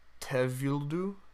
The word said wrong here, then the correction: Tevildo: Tevildu? – I think it's Tevildo. – Tevildo.